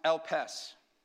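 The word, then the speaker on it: elpes (ἐλπίς): In the Greek, it's pronounced elpes. Elpes.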